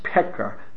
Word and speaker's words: Pecker: Finally, in regard to your quotation of Reb Moshe Chaim Alta Pecker's account of his survival arrived in the U.S., his surname is pronounced Pecker. The I is silent.